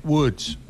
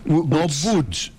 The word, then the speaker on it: Woods: Tiger Woods. – Woods. – Y si quiere le meto una roja. – No, es para aprender en serio. Woods. – Boots. No Boots, como usted dijo. – Tiger Woods.